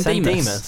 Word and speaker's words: San Dimas: What? How do you pronounce it? – San Dimas.